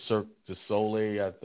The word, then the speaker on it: Cirque du Soleil: We also saw this show, Michael Jackson's One, the Cirque du Soleil, that's how you pronounce it.